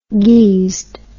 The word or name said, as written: geesed